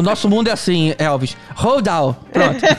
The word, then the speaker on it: Roald Dahl: baseadas nos livros do Roald Dahl. Não, Roald Dahl. Tá vendo? Nem sempre eu sei pronunciar as coisas em inglês.